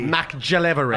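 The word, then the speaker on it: McGillivray: Dude, I have the Australian voice on my phone, on my old phone, and every time I'd say, I'd be like, call Taylor McGilvery. Calling Taylor McGillivery. – It's a hard G. – McGillivery.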